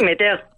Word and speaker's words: Metairie: La ciudad alberga sacramentales asombrosos como el ya citado cementerio de San Luis y otros tales como el cementerio de Metairie, que no sé si lo pronuncio bien. – Sí, Metairie. – Metairie.